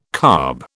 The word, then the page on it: carb: British English